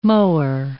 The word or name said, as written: mower